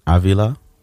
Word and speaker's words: Avila: The other girl, Rosalie Avila, did I say that right? – Avila. – I don't have the tongue to say that. Can you say it? You got the Spanish. – Rosalie Avila.